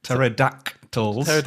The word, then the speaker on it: pterodactyls: Pterodactyls. – The P is silent.